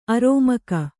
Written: ಅಱಮಗ

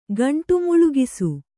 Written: ಗಂಟುಮುಳುಗಿಸು

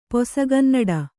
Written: ಪೊಸಗನ್ನಡ

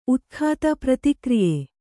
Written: ಉತ್ಖಾತ

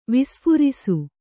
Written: ವಿಸ್ಫುರಿಸು

